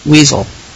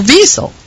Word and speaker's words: Wiesel: But here on the Heretics Hour, we are sticklers for accuracy, and the name will be pronounced weasel, or weasel, which is even more correct.